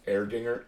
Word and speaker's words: Erdinger: It's called Erdinger, E-R-D-I-N-G-E-R, or E-H, but it's pronounced Erdinger, Erdinger. – Probably mm-hmm. E-H-R.